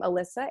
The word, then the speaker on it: Alyssa: It is my first name, Alyssa, A-L-Y-S-S-A. And then an underscore. And my last name is pronounced Shifrel, S-H-I-F-R-E-L.